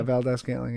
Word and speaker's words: Valdez-Scantling: Yeah, Valdez-Scantling.